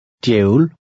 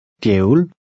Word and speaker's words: Djævel: In number 5, the DJ combination sounds like DY. Try to read number 5. – Djævel. Djævel.